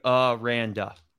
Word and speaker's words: Aranda: This is where I have Dave Aranda. There we go. – Yes. – I had to write it out. Like you said, Ryan, losing record at Baylor. However, the question for him is, what Dave Aranda are we going to see?